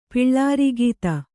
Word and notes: ಪಿಳ್ಳಾರಿಗೀತ: piḷḷāri gīta

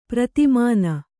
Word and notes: ಪ್ರತಿಮಾನ: pratimāna

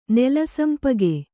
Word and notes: ನೆಲಸಂಪಿಗೆ: nela sampige

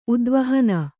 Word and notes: ಉದ್ವಾಹನ: udvāhana

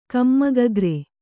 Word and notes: ಕಮ್ಮಗಗ್ರೆ: Kannada

